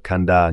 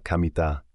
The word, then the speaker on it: Kamita: Nhưng nó không đọc là Kanda như anh tưởng. Nó được phát âm là Kamita.